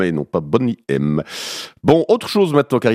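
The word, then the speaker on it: Boney M: Alors vous connaissez certainement le groupe des années 80-90, Bonnie M. Bon, elle prononce Bonnet M.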